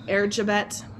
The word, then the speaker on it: Erzsébet: Or Elizabeth Bathory, it's Bathory, or Erzsébet, which is the Hungarian pronunciation of it.